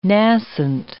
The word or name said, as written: nascent